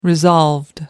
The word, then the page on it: resolved: American English